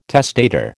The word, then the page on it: testator: American English